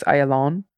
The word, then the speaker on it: Ayalon: Ayalon, with the accent on Hebrew on the final syllable. So it's Ayalon.